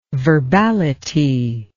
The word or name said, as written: verbality